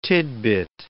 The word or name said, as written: tidbit